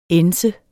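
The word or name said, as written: ænset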